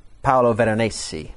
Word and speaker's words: Paolo Veronese: He was speaking about The Wedding at Cana, and there's a work of art by a guy called Paolo Veronese.